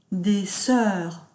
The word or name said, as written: des soeurs